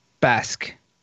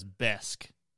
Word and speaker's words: Besk: Malort falls in the category of uh, Brenvin remain uh, known as Basque. – I saw another that said it's pronounced as Besk. Besk. So just say it as instead of an A, there's an E.